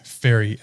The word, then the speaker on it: Faerie: It's Fairy, F-A-E-R-I-E.